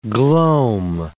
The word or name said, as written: gloam